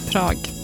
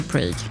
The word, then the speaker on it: Prag: Tydligen så uttalas Prag likadant på engelska som på svenska typ. Svårt. – Prag. – Man vill ju säga Prague. Ah, ja.